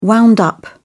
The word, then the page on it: wound up: British English